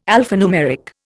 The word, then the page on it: alphanumeric: Indian